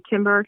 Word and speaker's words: Kimber: On Instagram, you can search on my name if you spell it correctly, C-H-A-W-N-E, last name Kimber, K-I-M-B-E-R. My handle is Koshy Complete, C-A-U-C-H-Y-C-O-M-P-L-E-T-E.